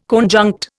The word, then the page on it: conjunct: Indian